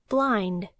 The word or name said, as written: blind